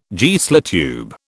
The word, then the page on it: Geissler tube: British English